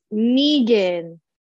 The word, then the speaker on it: Knigin: It is K-N-I-G-N. How do you say your last name? – K-N-I-G-I-N. – G-I-N, yes. – It's Negan. – Negan. – No, you, for some reason you said it right now, but you didn't. – Okay. – Like, it's Negan, like from Walking Dead? – Yeah.